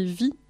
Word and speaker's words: Viy: C'est Vie, ça s'écrit V-I-Y.